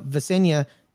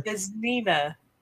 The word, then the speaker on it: Vesnina: Elena Vesenia is expecting her second child, having had a daughter. – Vesnina.